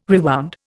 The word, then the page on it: rewound: Indian